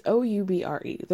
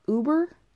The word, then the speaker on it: Oubre: it's Oubre. There's really no, you can't really get too fancy with it. Like, you know what I mean? There's no other way to pronounce that. Oubre, maybe? Uber? Maybe Uber. Maybe people say Uber. People are stupid.